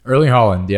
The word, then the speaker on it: Erling Haaland: Erling Haaland, yep.